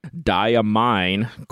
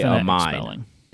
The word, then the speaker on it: Diamine: Alan sent an email to the Diamine Corporation to get the correct spelling, and straight from the email, they spell it DYE-A-MINE. – That's diamine. Phonetic spelling. – Thank you. The phonetic spelling, diamine. A mine.